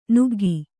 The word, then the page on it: ನುಗ್ಗು: Kannada